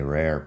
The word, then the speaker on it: rare: La pronunciación es interesante, rare, porque tiene la R y la otra R, rare, R-A-R-E, lo tienes por escrito en el nombre de este podcast.